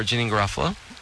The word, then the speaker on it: Janeane Garofalo: And so for Janine Garofalo.